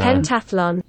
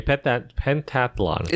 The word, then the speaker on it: pentathlon: Pentathlon. – Pentathlon. Okay, pentathlon. – Is it TH? Is there a TH in it? – Yeah, in the middle. T-A-T-H. Pentathlon. – T-A-T-H. – It's three syllables.